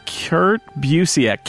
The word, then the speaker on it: Kurt Busiek: It would be Kurt Busiek. Kurt Busiek. Kurt. That's is it Kurt? Kurt. Kurt. it's just Kurt.